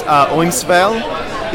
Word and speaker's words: Eumeswil: And we're talking about Ernst Jünger's Eumeswil. Is that good? – That's good, yeah.